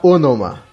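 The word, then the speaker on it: Ænima: Segundo o Alan Jones, ele fala que se pronuncia Onoma.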